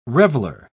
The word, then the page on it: reveler: British English